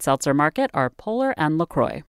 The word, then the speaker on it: LaCroix: Choi is a cultural correspondent, and she came out in the New York Times Magazine as a full-blown LaCroix addict. – Yes, that's really how it's pronounced.